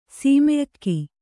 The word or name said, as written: ಸೀಮೆಅಕ್ಕಿ